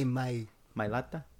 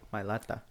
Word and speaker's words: Mailata: And then in the third round, they get him Patrick Mahomes a protector and Jordan Mailata from the tackle formerly of the Eagles. – Say Mailata. – There we go. Say it right. – Mailata.